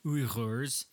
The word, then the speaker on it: Uyghur: Uyghurs is... Uyghur. – Yeah. – And I'm probably overpronouncing that a little bit, but it's more like that than Uyghur, but Uyghur is hard for, you know, a CNN anchor to say.